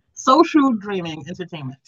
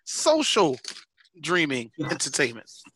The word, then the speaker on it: Social Dreaming Entertainment: Today we have with us Soulful Dreaming Entertainment. – No. Social Dreaming Entertainment. – I always say it wrong, and I've not been practicing. That's the sad part. I'm sorry. Social Dreaming Entertainment.